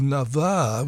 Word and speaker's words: Navarre: And Polly Baguet is from a duo now called Navarre, with the accent on the second A. Navarre, is that how you'd pronounce it?